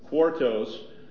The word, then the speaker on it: quartos: Quartos.